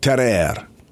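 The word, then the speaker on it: Tarrare: Today I'm going to tell you about Terrere. Terrere. Terrere. It's a French word. I'm trying to pronounce it authentically.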